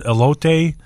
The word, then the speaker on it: elote: And they also make this, Marianne's, if she's listening, is going to get mad at me because I'm probably going to mispronounce this, the corn elote. – That's it.